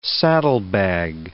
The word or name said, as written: saddlebag